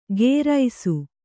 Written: ಗೇರೈಸು

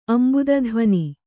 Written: ಅಂಬುದಧ್ವನಿ

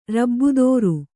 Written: ರಬ್ಬುದೋರು

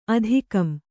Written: ಅಧಿಕಂ

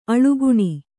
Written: ಅಳಿಗಣ್

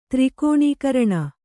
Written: ತ್ರಿಕೋಣೀಕರಣ

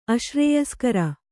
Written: ಅಶ್ರೇಯಸ್ಕರ

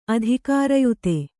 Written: ಅಧಿಕಾರಯುತೆ

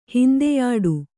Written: ಹಿಂದೆಯಾಡು

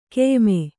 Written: ಖಾಯಮ್ಮು

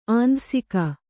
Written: ಆಂಧಸಿಕ

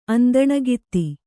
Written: ಅಂದಣಗಿತ್ತಿ

